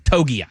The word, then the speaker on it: Togiai: Kobe Turner, Byron Murphy II, Tavondre Sweat, Moro Ojomo of the Philadelphia Eagles, and Tommy Tongai. Is it Tongai? Is that how you pronounce his last name from the Texans? – Togiai.